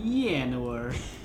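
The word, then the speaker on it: Llanowar: I think it's Spanish. Yanowar.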